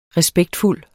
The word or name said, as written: respektfuld